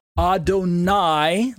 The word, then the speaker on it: Adonai: The Hebrew word translated my Lord is Adonai, is Adoni, pronounced A-D-O-N-E-E. So be very careful to watch for the pronunciations in this particular explanation. Adonai, listen to the last vowel, I, compared to Adoni, last vowel or second vowel, E.